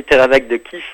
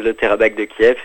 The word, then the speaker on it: Terabak de Kiev: Terabak de Kiev, faut-il dire la bonne prononciation peut-être déjà pour moi? – Bah, très bien, oui, Terabak de Kiev. La plupart des gens disent le Terabak de Kiev et c'est très acceptable aussi.